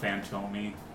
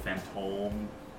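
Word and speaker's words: Fantôme: Oh, it's got the little house above the O. If it's like that, you have to pronounce it like an O. So, not Fantomi, but Fantome.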